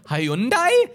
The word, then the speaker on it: Hyundai: And they go, Hyundai, pronounced like Sunday. Do you remember this? Because no one could pronounce it. They're like, Hyundai?